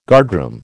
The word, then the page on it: guardroom: American English